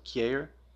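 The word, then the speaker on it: Kjaer: It's K J A E R. I'm not exactly sure how to pronounce it. I spent some time in Germany, and so I'm assuming the J is going to have that Y sound, so Kier.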